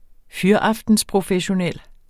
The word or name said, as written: fyraftensprofessionel